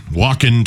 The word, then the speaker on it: Wacken: Wacken 2026. – Spell it. – W-A-C-K-E-N.